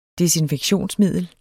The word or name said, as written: desinfektionsmiddel